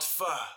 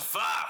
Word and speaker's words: pho: It's called pho. – That's even PODTrump knows. Aren't you embarrassed about that? – Trump is doing it. Yeah. – It's called pho.